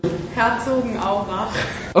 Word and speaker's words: Herzogenaurach: They lived in this town that, I'm going to totally mispronounce it, Herzogenaurach. If you don't, see now, can you just pronounce it for us? Just Herzogenaurach.